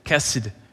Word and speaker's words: chesed: It's a Hebrew word called hesed, chesed. H-E-S-E-D is how you spell it, chesed.